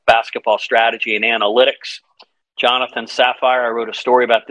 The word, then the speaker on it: Safir: spelled S-A-F-I-R, but it's pronounced Sapphire.